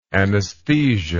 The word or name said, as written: anaesthesia